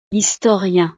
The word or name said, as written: historien